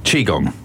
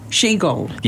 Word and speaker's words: Qigong: Qigong. – Qigong.